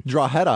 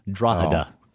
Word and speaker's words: Drogheda: Drogheda, Ireland. I mean, I knew Drogheda. – That one. – Drogheda.